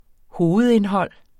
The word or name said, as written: hovedindhold